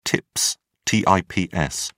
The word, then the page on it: tips: British English